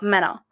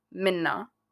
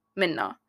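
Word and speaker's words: Menna: It's Mena, or how you actually pronounce it is Minna. Minna. Because it's Middle Eastern, it's an Arabic name.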